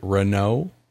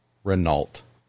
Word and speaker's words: Renault: Renault, Alpine, with something would indeed be a mouth-watering prospect. – I'm compelled to mention, that in my household, the word Renault was never pronounced. It was Renault.